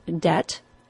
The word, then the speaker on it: debt: Two other words that were messed up by the scholars were the words debt, D-E-B-T, and doubt, D-O-U-B-T. Neither of those words have a B sound either.